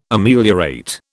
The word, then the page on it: Ameliorate: British English